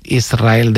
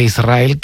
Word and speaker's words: Israel: Y es difícil, Y de diminutivo, de chiquitito, Israel, que ella quería, porque Israel creo que le sonaba muy grande, ¿no? Israel. – Y la S con la R es difícil de pronunciar.